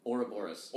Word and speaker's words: Ouroboros: So the album is called Ouroboros. Am I saying that right? – Ouroboros.